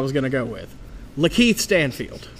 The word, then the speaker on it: Lakeith: How does Lakeith Stanfield pronounce his name? Is it Lakeith? – It's Lakeith? – Yes.